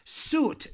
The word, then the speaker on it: suit: Suit. Esto me encaja. This suits me. Se escribe subit, pero la I. Fuera la I. Lejos de aquí.